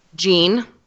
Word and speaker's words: Jean: Jean. As in like denim. Like, you know. – Cool. – Or also, although not spelled like it, as in like the building blocks of life.